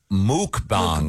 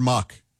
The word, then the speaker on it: mukbang: No, the word, and it's pronounced mukbang. – Mukbangs. – Mukbang. – Mukbang. – Yeah. It's actually pronounced muk, yeah. – Is it muk? – Mukbang. – Yep. Oh, I thought it was mukbang. – The pronouncer here is M-O-O-K. – Oh, okay. I've always heard muck. The people who do it, I've heard say muck. – It's spelled M-U-K-B-A-N-G.